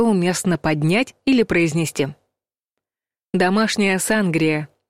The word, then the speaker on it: сангрия: Зато есть рекомендация — ставить ударение на предпоследний слог — «сангрия». Даже если чаще вы слышали вариант с ударением на первом слоге.